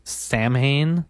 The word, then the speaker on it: Samhain: it's spelled S-A-M-H-A-I-N, which you would think would be Samhain. – Mm-hmm. – But it's actually pronounced Samhain.